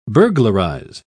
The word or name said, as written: burglarize